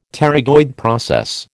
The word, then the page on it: pterygoid process: American English